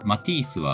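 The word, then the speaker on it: Matisse: これは、ま、マティースは、マティース。 T がアクセントかな。 – まあ、 T ですね。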